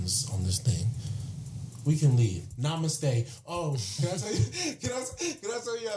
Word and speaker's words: namaste: And then at one point, I was like, namaste.